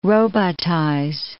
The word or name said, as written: robotize